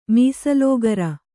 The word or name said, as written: ಮೀಸಲೋಗರ